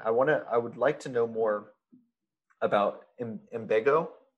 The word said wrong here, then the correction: Ambigo: How do you say it? Imbigo? – Ambigo. Ambigo. Go, because we help ambitions go places.